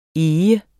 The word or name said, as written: EGERNE